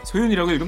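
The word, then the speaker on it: So!YoON!: 소윤이라고 읽으면 되는 거죠.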